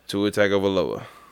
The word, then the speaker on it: Tua Tagovailoa: Let's get a solid pronunciation on it. – Tua Tagovailoa. – Okay.